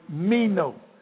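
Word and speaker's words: meno (μένω): C-O-N-T-I-N-U-E. That comes from a Greek word, meno. M-E-N-O, with the emphasis on the O.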